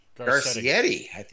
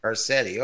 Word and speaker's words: Garcetti: During a press conference Wednesday night, Mayor Eric Garcetti, is how he pronounces it. – Garcetti, okay.